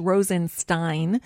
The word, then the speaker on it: Rosenstein: Those same lawmakers have actually drawn up articles of impeachment for Deputy Attorney General Rod Rosenstein, pronounced Rosenstein, who is overseeing the Mueller probe.